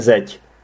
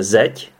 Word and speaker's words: zeď: Zeď, tak zeď, počkejte, já to čtu jako zeď, což je blbost, ono se to čte zeď. Jo, ono, to je další zajímavost vlastně, že někdy, počkejte, zeď, ano, je to tak, zeď se píše z dž a čte se to z ť.